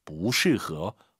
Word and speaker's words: 不适合: 안 입을 자 사성이에요. 근데 뒤에가 사성이면 이성으로 바뀌죠. 不适合, 不是 아니에요.